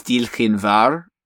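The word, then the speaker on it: diolch yn fawr: One of the few phrases in Welsh I can say is Dilchin Var, which is, it's spelled like D-I-O-L-C-H space Y space F-A-W-R, which means like thanks a million.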